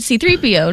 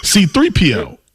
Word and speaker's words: C-3PO: Your emphasis is on the C. – C-3PO. – Iron's is on the 3. Mine's on the 3. P. C-3PO. – But you're making it sound like two different words.